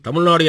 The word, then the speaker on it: தமிழ்நாடு: வட இந்தியர்களுக்கு இழகர உச்சரிப்பு வராது. தமிழ் அல்லாதவர்கள் அதை இழாவை உச்சரிக்க முடியாது என்பதனால் தமிழ்நாடு என்பதை ஆங்கிலத்தில் தமிழ்நாடு என்று உச்சரிப்புக்கு வசதியாக மாற்ற ராஜாஜி திமுக அமைச்சரவைக்கு ஒரு ஆலோசனை தந்தார்.